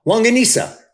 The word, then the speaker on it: longanisa: I'm going to butcher this. Longancina? No, wait, nope. That's like John Cena. Yeah, Longancina. Wait, no, I'm still saying it wrong. Longanisa. Longanisa. – Longanisa. – Longanisa. Not John Cena.